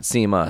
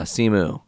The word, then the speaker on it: Simu Liu: Simu Liu. Not Sima, Simu. S-I-M-U-L-I-U. He has done.